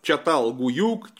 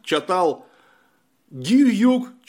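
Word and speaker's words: Çatalhöyük: Он по-разному пишется. Чатал-Гуюк, Чатал-Гююк, Чатал-Хююк через 2 Ю. В общем, выберите то, что вам нравится. Это, в конце концов, русское произношение из турецкого языка.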